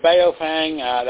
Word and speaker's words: Baofeng: Well, the Baofeng, that's how I pronounce it.